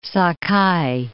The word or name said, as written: Sakai